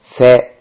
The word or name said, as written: se